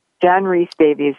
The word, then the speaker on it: Rhys-Davies: Am I pronouncing his name correctly? I just want to stop, Mimi. – It's John make sure I'm pronouncing. I hate to mispronounce somebody's name. – John Rhys-Davies. Yeah, it's a hard one.